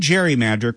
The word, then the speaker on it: gerrymander: But when the term gerrymander caught on, it wasn't with the hard G sound of Gerry's surname.